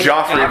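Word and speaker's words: Geoffrey: for Jeffrey Finch. Yep, uh, I did uh, in in Joffrey. I think we we, we, we always pronounced Joffrey? it Joffrey, Joffrey, but I know so it was G E O.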